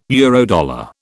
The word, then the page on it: Eurodollar: British English